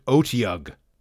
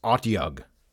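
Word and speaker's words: Otyugh: But for this next creature from the Monster Manual, a classic, I always pronounced it Otiug. But I believe that that is mistaken. I believe I am incorrect. Because according to Frank Mencer's Dragon article from Dragon number 93, it is Otiug.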